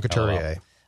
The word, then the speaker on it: Couturier: I looked up the pronunciation. It's small K-O-O-T. – Small K, yeah. – I think it's Sean Couturier.